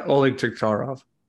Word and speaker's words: Oleg Taktarov: Yeah, Oleg Taktarov.